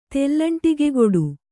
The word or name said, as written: ತೆಲ್ಲಂಟಿಗೆಗೊಡು